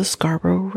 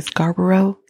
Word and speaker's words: Scarborough: He started off his reign of terror as what we call the Scarborough Reapers. Now, for those who've listened to the story and know the story, you might hear Americans say Scarborough. It's Scarborough. Scarborough Rapist.